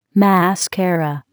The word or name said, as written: mascara